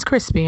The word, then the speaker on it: Chrispy: And my name is Crispy, and that's spelled C H R I S P Y.